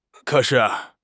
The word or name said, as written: ke3 shi4